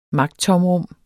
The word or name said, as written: magttomrum